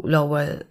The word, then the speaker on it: Lowell: Wenn unsere Zuhörer und Zuhörerinnen jetzt auch noch gerne ein Bild zu Lowell, wahrscheinlich habe ich es jetzt voll falsch ausgesprochen. Aber ich Nein, du hast es auch schöner ausgesprochen als ich. Das Problem ist, ich bekam es nicht federfrei hin. Also es wird Lowell geschrieben.